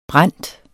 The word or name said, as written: brændt